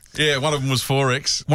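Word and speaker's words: XXXX: 4X, pronounced 4X, is a brand of Australian beer brewed in Milton, Brisbane by Queensland brewers Castlemaine Perkins, now a division of the Japanese-owned Lion.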